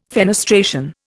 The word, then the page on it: fenestration: Indian